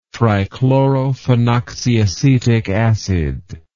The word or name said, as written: trichlorophenoxyacetic acid